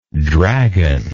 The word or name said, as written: dragonlike